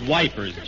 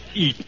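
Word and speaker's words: Ypres: It's not pronounced Wipers, General. It's Eep.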